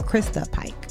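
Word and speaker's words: Christa Pike: Her name is Krista Pike. I've been saying that wrong. Krista Pike.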